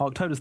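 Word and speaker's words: Arctodus: And he wants to know, what would win a fight between a large Arctotis? Am I saying that right? – Mm-hmm. Arctotis, the bear, yeah.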